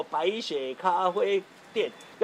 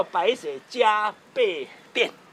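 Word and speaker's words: 白雪珈琲店: 哦，好，啊，很多人说啊，这个现在我们这栋呃文化局整修完了之后，啊，委外经营嘛，哈、哦，这个这个不是叫不是念作不是念作白雪咖啡店，叫白雪加倍店。这个念作加，这是日本的汉字。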